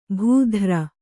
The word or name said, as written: ಭೂಧ್ರ